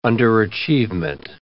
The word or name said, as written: underachievement